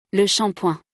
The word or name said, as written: Le shampoing